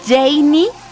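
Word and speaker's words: Jeywny: Recuerden, también pueden seguirme en redes sociales, @ Janie Capistrán. Mi nombre se escribe así, J-E y latina, W-N-Y, Capistrán. Así me llamo, así me encuentras en redes sociales.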